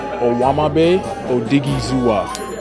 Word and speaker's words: Owamagbe Odighizuwa: All right, so this is the correct pronunciation of my name. Owamabe Odighizuwa.